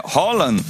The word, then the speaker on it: Haaland: Mbappé, Haaland, que não é Haaland, viu? Ele ensinou. Foi ele que ensinou. Ele falou: a pronúncia do meu nome é Haaland. É Haaland, Messi, Cristiano Ronaldo.